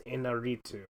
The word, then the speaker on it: Iñárritu: Birdman, or The Unexpected Virtue of Ignorance, written and directed by Alejandro González Iñárritu.